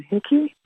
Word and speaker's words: Hickey: I'm Marilyn Hickey. Rhymes with sticky.